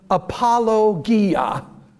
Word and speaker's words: apologia: And the Greek word translated as give an answer is a word, literally it's pronounced apologia, from which we get the English word apologetics.